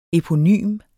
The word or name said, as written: eponym